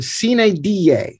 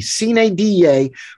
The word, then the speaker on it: sine die: And that is the phrase, it's pronounced in Latin, sine die. That is S-I-N-E space D-I-E, sine die. But you often hear it pronounced in English by people who know nothing about Latin. They don't even probably know it's Latin. They say sine die.